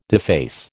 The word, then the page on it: deface: American English